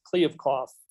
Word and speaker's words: Kliavkoff: Actually, I heard it pronounced a couple different ways, and I'm going to go with Kliavkoff.